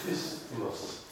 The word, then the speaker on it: isthmus: Right, firstly, somebody say the word isthmus for me that I can't say properly. – Isthmus. – Thank you. Excellent. Prize at the back for his birthday and for saying it correctly.